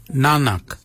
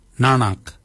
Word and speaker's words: nangag: So for neck we say nanak, nanak. Nanak is spelled N-A-N-G-A-G. Nanak, nanak is your neck. Chin, we say yet, yet for chin. Yet is spelled Y-E-D.